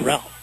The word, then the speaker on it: Gerald: W-H-I-S-O-N-A-N-T. – Yeah, I think they said they pronounced him Jarrell.